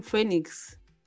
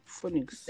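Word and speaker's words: Phoenix: Joaquín Fénix. – Sí. Ajá. ¿Se escribe Pónix o cómo se escribe? Fénix.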